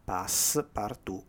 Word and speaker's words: Passepartout: E quindi esce tale Passepartout, scritto come si legge, Passepartout con l'accento sulla U, operazione 00.